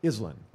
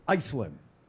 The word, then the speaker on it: Iselin: Now, the city that it's in, because my wife was born and raised there, is called Island, Iselin, okay? And so Dave's talking about, I'm actually driving here to the studio because I had a show at 9 a.m., and he pronounces it Iceland.